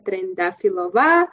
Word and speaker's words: Trendafilova: Yes, my name is Militsa Trendafilova.